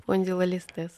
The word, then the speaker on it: спондилолистез: Если детский ортопед говорит, что есть небольшой э, а, спондилогистез, наверное, да? – Листез. Что это? – Листез. Скажет, как правильно слово произносится. – Спондилолистез.